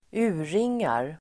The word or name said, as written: urringar